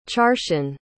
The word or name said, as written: charsian